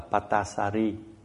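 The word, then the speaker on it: Patācārā: Thì có một cái câu chuyện là có một cái cô nọ tên là Patasara, mà người Miến thì thường đọc là Patasari.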